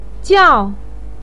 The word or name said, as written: jiao4